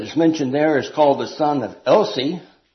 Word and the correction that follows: Esli: That's, I said it wrong, Esli, in Luke chapter 3, verse 25.